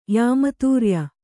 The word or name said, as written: ಯಾಮತೂರ್ಯ